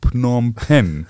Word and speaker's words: Phnom Penh: Ich fürchte aber, wenn wir zu viel über das Buch jetzt sprechen, detailliert, also über die Python in Phnom Penh zum Beispiel.